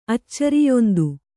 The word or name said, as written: ಅಚ್ಚರಿಯೊಂದು